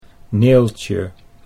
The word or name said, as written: Neeltje